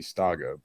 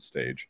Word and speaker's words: Stage: That's how I remember his proper pronunciation, because I thought it would be Staga, but it's the Dane. They pronounce it stage.